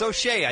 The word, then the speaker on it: O'Shea: Let's hear it for O.J. Jackson Jr. O.J. Jackson Jr.? – It's O'Shea, I think.